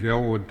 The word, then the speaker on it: Elwood: My name is Elwood Thomas.